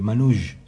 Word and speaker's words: Manouche: Jazz Manouche, se escribe, Manouche se pronuncia, en francés, una palabra francesa ah, creo.